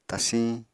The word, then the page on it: tasiñ: apatani